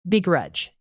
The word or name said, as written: begrudge